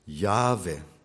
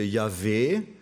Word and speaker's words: Jahwe: Es gibt auch die Betonung auf der letzten Silbe, Yahweh, das machen die orientalischen Juden, wenn sie den Namen aussprechen täten. Also Yahweh ist mehr die orientalische Betonung, Jahwe ist mehr die europäische. Was definitiv falsch ist und was ich vielen Theologen schon gesagt habe, ist Jahwe. Es gibt kein Ch in diesem Namen. Es ist Jahwe.